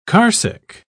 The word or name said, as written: carsick